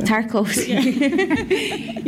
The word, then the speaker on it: Tarkovsky: What's the, you know, I can't say his name, but is it Tarkovsky? – Tarkovsky.